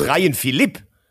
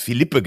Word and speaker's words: Ryan Philipp: Du meinst Ryan Philipp? – Philipp. – Ja, du hast Philippe gesagt. – Wird doch auch Philippe ausgesprochen. – Nee, Ryan Philipp als Luxemburger, Französisch.